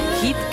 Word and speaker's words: Heat: Hit, que se escribe H-E-A-T. Hit. Cadena Hit.